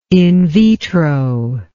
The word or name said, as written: in vitro